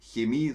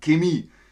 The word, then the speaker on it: Chemie: Wörter mit ch werden meist mit K ausgesprochen, wie in Bayern auch, zum Beispiel nicht Chemie, sondern Chemie und nicht China, sondern China. Chiemsee bleibt Chiemsee. In Deutschland sind auch die Aussprachen mit Sch, wie zum Beispiel im Osten. Da sagt man Chemie und China und Chirurg.